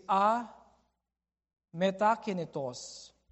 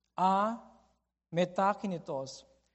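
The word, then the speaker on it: a metakinetos (ἀμετακίνητος): I'm going to pronounce it to you so you can see the two parts. In Greek, it is a metakinetos. A metakinetos.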